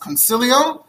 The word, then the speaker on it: Consiglio: Then in 1425, there's a Jew named, well, it's spelled Consiglio, but it's apparently pronounced Consilio.